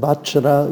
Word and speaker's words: Vajra: Also äh, ich würde mal von diesem Vajra, über das würde ich mit rüber trauen, also das J wird als J ausgesprochen.